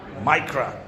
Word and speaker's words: Micra: I think it was a Nissan Micra or Micra. I don't know. How do you pronounce that? Micra. British.